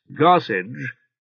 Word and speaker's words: Garsecg: G-A-R-S-E-C-G, pronounced Garsage, spelled G-A-R-S-E-D-G-E, and so written in Alfwena A, was one of the many old English names of the sea.